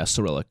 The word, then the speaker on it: Cyrillic: Acrylic. Is that how you pronounce the Russian alphabet? Acrylic? Cyrillic? – Yeah, Cyrillic.